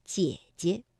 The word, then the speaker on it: 姐姐: 那么，姐姐本来啊，这个赏声也应该读 214，姐。但是当连读的时候，第一个姐呢，我们就把它读成 21，姐姐。